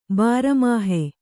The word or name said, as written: ಬಾರಮಾಹೆ